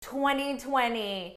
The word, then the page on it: 2020: American English